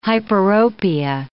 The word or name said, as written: hyperopia